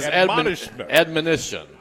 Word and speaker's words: admonition: Now, his, how do you say that one? – His admonition. – Admonition. I always say it in a different verb tense.